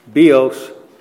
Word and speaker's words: bios: We would spell it B-I-O-S, pronounced bios in the Greek. I is pronounced like an E, bios. The other is zoe. We would spell it Z-O-E.